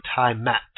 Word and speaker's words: Tiamat: It's spelled T-I-A-M-A-T. It's probably Tiamat. Okay? Tiamat.